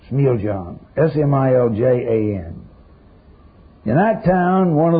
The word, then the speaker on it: Smiljan: In the year 1856, in the small Yugoslavian town of Smiljan, or Smiljan, a Yuka that can pronounce in Yugoslav.